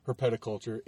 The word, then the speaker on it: herpetoculture: But anyways, he pronounces it Herpeticulture. It's in the name of his store, and he's much smarter than I am, so I'm going to go with Herpeticulture.